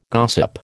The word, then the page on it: gossip: American English